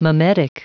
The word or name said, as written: mimetic